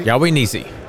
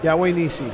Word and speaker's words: Yahweh Nissi: Am I saying that right? – Yahweh Nissi. – Yahweh Nissi. – Yep, the Lord is my banner.